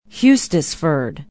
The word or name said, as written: hustisford